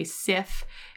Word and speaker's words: CIFF: Oh, I guess I should say SIF. It's not a hard C, it's like an S, SIF.